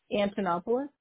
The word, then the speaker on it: Antonopoulos: Antonopoulos. – Oh, will you spell that one for me? – Yep, A-N-T-O-N-O-P-O-U-L-O-S. – A-N-T as in Tom, O-N-O-P-O-U-L-O-S.